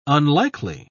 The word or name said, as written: unlikely